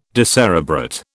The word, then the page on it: decerebrate: British English